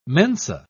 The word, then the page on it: mensa: British English